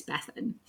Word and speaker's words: Bethan: Although I do stand by the fact that it's the Welsh pronunciation anyway is Bethan. – Yeah. – Although we realised that maybe her name was Beth Ann.